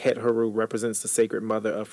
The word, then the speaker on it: Hathor: which was originally pronounced het-heru by the ancient Egyptians.